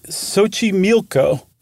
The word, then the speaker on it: Xochimilco: It's Lake Xochimilco. And friends, look up Xochimilco. It is not in any way, shape, or form, as far as an English speaker is concerned, spelled like it's pronounced.